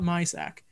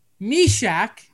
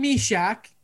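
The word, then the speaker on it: Myšák: It's not Mysak. Meshack. Jan Meshack, Canadian second round pick and captain for the squad, getting the only goal for the Czechs.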